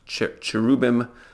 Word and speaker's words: Cherubim: It seems to be pronounced Cherubim, something like that, Cherubim.